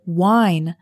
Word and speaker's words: whine: Speaking of complaining, we also have the word whine. Whine means to complain in an annoying, high-pitched voice. Whine is spelled W-H-I-N-E, but the H is silent.